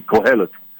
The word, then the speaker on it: Kohelet: Kohelet.